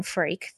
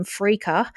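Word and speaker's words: Freke: I also do need to apologise to Macklin Freak, the goalkeeper for Brisbane Roar, because A, I pronounced his name wrong in the last pod. I tried to Scandinavian it up and was calling him Freaker.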